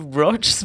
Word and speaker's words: Broch: Jeg tror det uttales Broch.